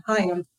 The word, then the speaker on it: Hyam: Hyam. – Hyam. I'm never going to get that right now. I have to remember that. Like, how high I am.